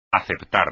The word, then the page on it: aceptar: espagnol